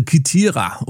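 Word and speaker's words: Kythira: Ja, das war die Insel Kithira, oder wie der Engländer sagt, Cythira. Ja, also hier, ihr könnt es aussprechen, wie ihr wollt, auf jeden Fall eine griechische Insel.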